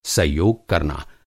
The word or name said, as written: सहयोग